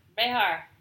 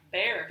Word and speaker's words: Bexar: B-E-X-A-R. – Behar. – Bear. – That's what? – Bear.